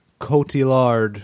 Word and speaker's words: Cotillard: Cotillard. Mm. I don't know how to pronounce her name. I'm sorry. – That's close enough. – Cotillard.